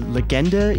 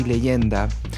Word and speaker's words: Legenda: Is it the Legenda EP? Am I pronouncing that correctly? – Yes, you're pronouncing it correctly. In Spanish, it would be Leyenda.